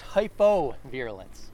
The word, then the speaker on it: hypovirulence: So you might want to make sure you're really clarifying that pronunciation. The hypo versus hyper? – Yes, because I had to look that up several times. So it's hypovirulence. Hypo. So this is a relatively new strategy of biological control.